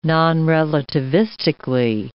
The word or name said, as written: nonrelativistically